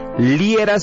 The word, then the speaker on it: Liera: Yo soy Fernando Liera. Mañana te espero en las calles de la ciudad de Guadalajara. Si quieres seguirme en redes sociales, bien sencillo. Me buscas por mi nombre, Fernando Liera, se escribe L-I-E-R-A.